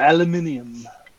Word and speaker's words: aluminium: Or aluminium.